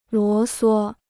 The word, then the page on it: 啰唆: luō suō